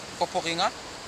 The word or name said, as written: popokinga